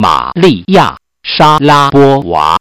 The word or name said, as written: shalapova